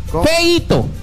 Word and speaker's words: feito: Yo diría que no eito. – Eito, no se dice eito. Hecho se dice así como, lo que es predicita. Feito. Feito.